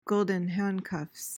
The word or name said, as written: golden handcuffs